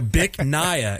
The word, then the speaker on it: Bich Nga: And the name of the restaurant, I'm just going to spell for you. Yeah. B-I-C-H-N-G-A. So, Bic Naya is how you say what? – It.